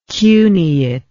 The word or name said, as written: cuneate